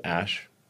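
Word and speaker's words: Æ: It's just X, the letter X, and then the AE is like pronounced Ash. And then A-12 is my contribution.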